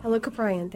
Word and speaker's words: Helicoprion: Helicoprion, thank you.